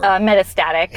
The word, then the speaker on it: metastatic: Metastatic.